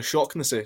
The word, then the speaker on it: Shaughnessy: I had a line in my notes that if Joe Shaughnessy was going to score, the Irish pronunciation of his name is actually Shocknessy, so I was going to call him the Shockness Monster.